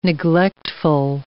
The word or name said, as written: neglectful